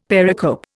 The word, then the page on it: pericope: Indian